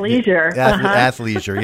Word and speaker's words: athleisure: Athleisure. – Yeah, that's it. Yeah, the ick is out of there. Just take the ick right out of that and then make things, athleisure, there we are.